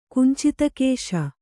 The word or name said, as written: ಕುಂಚಿತಕೇಶ